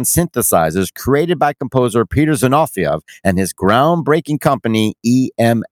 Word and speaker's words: Zinovieff: Zinof- Z- It's Russian. Zinovieff.